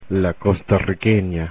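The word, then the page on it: costarriqueña: Spanish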